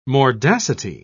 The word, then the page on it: mordacity: British English